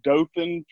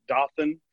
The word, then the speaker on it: Dothan: That's Dothan. For most people who aren't from here, they call it Dothan. We can always tell the out-of-towners when they mispronounce the name of the city.